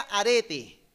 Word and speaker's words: arete: Ahora, fíjense que este vocablo virtud en griego eh, se pronuncia arete, ah, como los aretes de una mujer, pero sin la S.